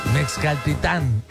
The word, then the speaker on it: Mexcaltitán: Híjole, qué rico nos la pasamos en tequila, qué rico nos la pasamos en Mexcaltitán. Mexcaltitán.